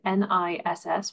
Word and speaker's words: NISS: or NISS, pronounced NIS,